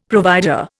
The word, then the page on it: provider: Indian